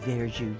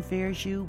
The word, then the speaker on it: verjus: Have you tried Verju yet? That's spelled V-E-R-J-U-S.